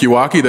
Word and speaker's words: Yawakie: My name is Patrick Iwaki. That's spelled Y-A-W-A-K-I-E.